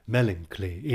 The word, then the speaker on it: melancholy: Melancholy here being pronounced as melancholy in three syllables, to scan properly.